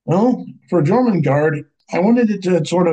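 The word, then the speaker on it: Jörmungandr: German guard? – Jormungandr.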